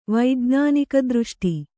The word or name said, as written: ವೈಜ್ಞಾನಿಕದೃಷ್ಟಿ